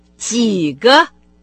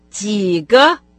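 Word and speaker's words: Ги га (几个): Второе слово со значением «сколько» – это «диго», «Ги» произносится с третьим тоном. – «Ги га», «Ги га», «Ги га».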